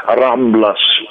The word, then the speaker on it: Рамблас: Дело в том, что бульвар называется Рамблас. Последняя буква «С», она в испанском читается.